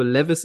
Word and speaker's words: Levis: Aber was mir aufgefallen ist äh, bei Will Levis, ja, ich glaube, Levis ist die richtige Aussprache.